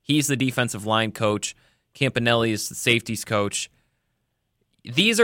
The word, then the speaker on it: Campanile: Anthony, apparently it's Campanelli. It's not spelled like Campanelli, but it's pronounced Campanelli.